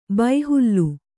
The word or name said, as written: ಬೈಹುಲ್ಲು